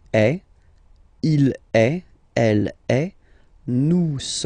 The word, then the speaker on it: nous: Here it's nous, nous. Okay, ah, sorry about that. Oops. Nous sommes. Okay, nous sommes. Okay, so you don't pronounce the final S here, it doesn't exist.